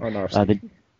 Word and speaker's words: Aronofsky: Aronofsky.